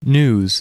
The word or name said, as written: news